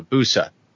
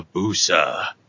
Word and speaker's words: Busa: Let's say I have a Busa. – With you're saying it wrong. – Oh, am I? – Yeah, you gotta say it deeper. – Oh, okay. Let's say I have a Busa.